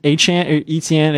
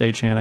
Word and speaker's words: Etienne: Etienne, it's pronounced Achan, I guess.